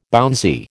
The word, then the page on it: bouncy: American English